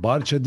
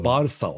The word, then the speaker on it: Barça: Barça değil o. Ben her zaman Barça diyorum. Barsa o. Çünkü İspanyollar bunu yani daha Katalanlar yani neyse İspanya İspanyolcadan Barcelona'nın kısaltmasını Barça yazıyorlar. O Barsa diye okunuyor. O harf S olarak okunuyor.